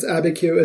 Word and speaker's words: Abiquiu: And if you're wondering why, I am going to be talking about a place called, and we always called it Abiquiu, but it could be something pronounced differently. – No, that is correct. It's Abiquiu.